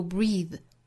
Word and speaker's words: breathe: 那这个词的名词形式就是呼吸这个词啊， breath 读成 breath，比如说 take a breath，吸一口气。那这个词里头的元音呢是 a 的发音，并不是紧音的 e 了。 a 的发音呢就相当于 set set 之中的 a 的发音，尾部 th 又是轻辅音 th 不是 v，所以这个词名词读成 breath，动词读 breathe，发音区别还是挺大的，我们要注意区分。